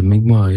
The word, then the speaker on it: Mi'kmaq: It feels really nourishing to hear the ways of the Mi'kmaq. I hope I'm saying it right. – Yeah, of course, the Mi'kmaq, yeah.